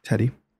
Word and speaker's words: Teddie: T-E-D-D-I-E. – Teddy.